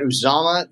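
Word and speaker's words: Uzomah: First of all, you get Uzoma, the tight end.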